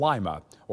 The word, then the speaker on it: Lima: They ultimately settled on the area around Springfield, Kentucky, and they dedicated that priory and church and school to St. Rose of Lima, or St. Rose of Lima, as you hear it sometimes pronounced as well.